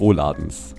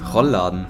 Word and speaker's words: Rollladen: Über Philips Gesicht laufen diese, also so Lichtflecken vom Muster des Rohladens. Also diese Rohläden haben wir. Des Rohladens? Rohladens. Wie ich Rohladens. – Rollladen.